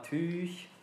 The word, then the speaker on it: TÜÜG: Äh, alles auch über TÜÜG, äh, Tüch.